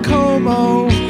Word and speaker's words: Como: You should be on Cuomo with Clooney. Should be shared with you. – Little fossil, I think it's pronounced Como. – Okay, so you all thought I was talking about disgraced New York brothers.